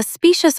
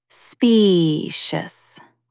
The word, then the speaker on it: specious: A specious argument might fool a crowd for a minute, but it falls apart under scrutiny. Stress the first syllable. Specious.